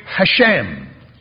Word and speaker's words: Hashem: And still where we read Lord there, you'll read Hashem.